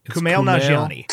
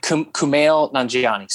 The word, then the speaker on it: Kumail Nanjiani: Kumail Nanjiani. – Kumail Nanjiani.